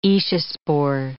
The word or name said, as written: aeciospore